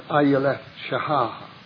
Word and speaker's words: Ayeleth Shahar: To the chief musician upon Ayeleth Shahar. That is more or less the Hebrew pronunciation of these letters.